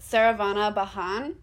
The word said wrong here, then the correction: Saravana Bhavan: But is that how you say it? I don't know. – Yeah. Saravana Bhavan.